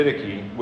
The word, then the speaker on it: Didache: There's other pronunciations of it, but the Didache, which is dated from around 75 AD to 150 AD.